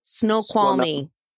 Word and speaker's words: Snoqualmie: Snoqualmie.